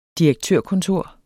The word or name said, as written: direktørkontor